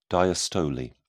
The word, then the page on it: diastole: British English